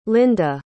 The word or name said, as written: linda